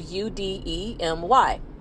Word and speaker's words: Udemy: It's spelled U D E M Y dot com, Udemy.